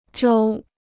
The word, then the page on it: 舟: zhōu